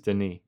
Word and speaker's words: Denis: She taught me how to say it. – Wait, like, so that's like the French pronunciation of it, or that's how he pronounces it? – I think it's the, well, she was like familiar with him because he like came from her area. So she was like, hey, it's Denis Villeneuve.